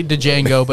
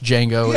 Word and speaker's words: Django: Yeah, you hear people say Django, but it's pronounced Django.